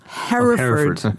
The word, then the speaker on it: Hereford: Yes, Hereford. – Yeah, because he goes... – That's what you want to do. – He goes Hereford, not Hereford.